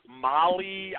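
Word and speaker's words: Mahle: Tyler Molly, I believe it's pronounced.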